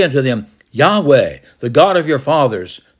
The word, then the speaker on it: Yahweh: The old translations was Jehovah, but that's not the correct pronunciation. It's Yahweh, the God of your fathers, the God of Abraham, the God of Isaac, and the God of Jacob has sent me to you.